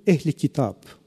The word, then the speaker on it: Ehl-i Kitab: Si nous avons des amis avec nous ce soir, qu'ils me permettent la prononciation, parce que c'est à la turque, Ehl-i Kitab, gens du livre,